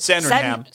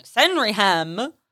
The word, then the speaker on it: Sandringham: Sandringham. – Sandringham.